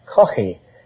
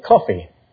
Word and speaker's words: Caughey: That's spelled C-A-U-G-H-E-Y. In Ireland, we'd call that Coffey with a guttural, but most Americans can't say Coffey, so they say Coffey.